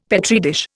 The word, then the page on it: Petri dish: Indian